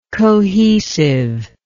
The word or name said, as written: cohesiveness